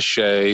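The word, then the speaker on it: Shea: My middle name is Shay, so people call me B-Shay.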